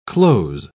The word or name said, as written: cloze